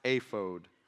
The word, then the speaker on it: ephod: You pronounce it however you wish, but it is pronounced ephod.